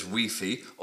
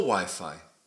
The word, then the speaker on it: Wi-Fi: Other technological words, like our example Wi-Fi, can be said as Wi-Fi or Wi-Fi. I've heard both so much now that I often use either equally. But I prefer Wi-Fi, as this seems the standard British and Australian pronunciation.